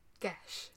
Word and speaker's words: Geis: It's spelt G-E-I-S, but it's pronounced Gesh.